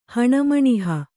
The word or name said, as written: ಹಣಮಣಿಹ